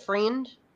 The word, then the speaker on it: Friend: Friend? – Friend. I think. Friend. It's spelled like friend.